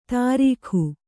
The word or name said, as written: ತರೀಖು